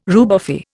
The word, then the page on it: rubefy: Indian